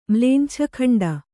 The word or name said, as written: ಮ್ಲೇಂಛಖಂಡ